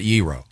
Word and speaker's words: gyro: Petros, Papa George, Euros. – And yeah. you pronounce it Euro. – No, yeah, I do. He's from Greece. It's 100% Euro.